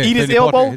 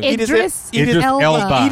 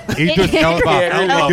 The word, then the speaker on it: Idris Elba: He's a good Idris Elba. – Eat his elbow? – Idris Elba. Get the name right, you piece of trash. – Eat his elbow. – Get the name right, you piece of trash.